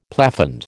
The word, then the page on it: plafond: American English